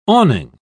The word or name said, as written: awning